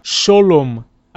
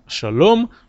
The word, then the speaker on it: שלום: В ашкенадском произношении, в частности, огласовка «камац» э, передается как «о», поэтому Э, Азриэль поет так. Шолом алейхем, шолом вместо шалом, шолом алейхем, малахея шорейс, малахей ильон, Мелех малахея млохим, опять же, млохим, акодойш борух у.